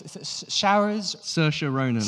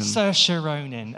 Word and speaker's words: Saoirse Ronan: the actress, Saoirse Ronan. – Saoirse Ronan.